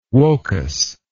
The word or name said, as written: wokas